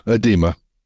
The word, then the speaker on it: edema: And I think it's called, and I may say this wrong, folks, is it edema? – Yeah, edema.